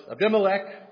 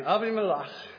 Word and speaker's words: Abimelech: And the king of Gerar, Abimelech, I know it's Abimelech, but I got into the Jewish commentary, so all week long I've been hearing Abimelech.